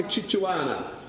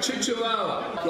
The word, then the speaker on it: Chihuahua: Kimberly said that she would fight like a Chichuana. Fight like a Chichuana. A Chichuana. I stand corrected on the pronunciation of the dog. She would fight like a chihuahua.